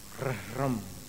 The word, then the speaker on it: रह्रम: रह्रम, ऊपर भी एक रेप है उसका पहले उच्चारण करना, र से प्रारंभ करना, फिर ह के बाद फिर से र बोलना और एक अनुस्वार रम, ये उसका उच्चारण।